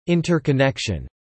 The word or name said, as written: interconnection